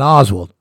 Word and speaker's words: Oswald: There was David Ferry and a person who I had seen in his house on 3 or 4 occasions, Leon Oswald.